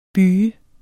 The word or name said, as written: byge